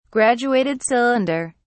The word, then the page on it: graduated cylinder: British English